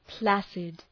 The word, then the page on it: placid: ‘plæsıd